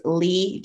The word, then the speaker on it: Leigh: So my pen name for those books is Lee James. So Lee is spelled L-E-I-G-H and then James, J-A-M-E-S.